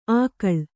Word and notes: ಆಕಳ್: ākaḷ

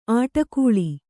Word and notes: ಅಟ್ಟುಗುಳಿ: aṭṭuguḷi

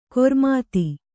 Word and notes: ಖರಾಮತ್ತು: Kannada

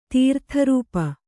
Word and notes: ತೀರ್ಥರೂಪು: kannada

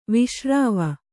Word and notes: ವಾಸರವ: vāsarava

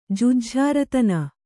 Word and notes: ಜುಜ್ಝಾರತನ: Kannada

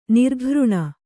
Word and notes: ನಿರ್ಘೃಣ: kannada

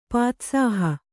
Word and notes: ಪಾತ್ಸಾಹ: pātsāha

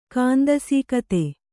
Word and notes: ಕಾಂದಸೀಕತೆ: kāndisīkate

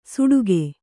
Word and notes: ಸುಡುಗೆ: suḍuge

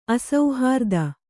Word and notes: ಅಸುಹೃತ್ತು: asuhřttu

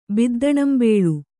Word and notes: ಬಿದ್ದಣಂಬೇಳು: Kannada

